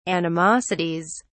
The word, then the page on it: animosities: British English